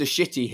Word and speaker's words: Shitty: And so when I first got his application, I was like, it can't be pronounced Shitty. And he phones me up and he goes, hello, it's Mr. Shitty here.